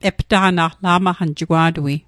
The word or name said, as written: la'maqan